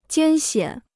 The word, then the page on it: 艰险: jiān xiǎn